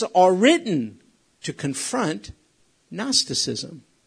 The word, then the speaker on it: Gnosticism: Gnosticism. Starts with a G, like Gnosticism, but the G is silent.